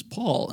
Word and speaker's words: Paul: Well, but my last name is Paul, and people find a way to screw that up, so I can only imagine.